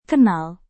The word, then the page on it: kenal: indonesian